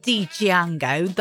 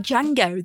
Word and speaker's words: Django: It is, and I've also, thanks to Craig, been advised that you actually pronounce, it's not Django that I always keep saying, it's actually Django.